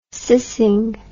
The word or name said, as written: cissing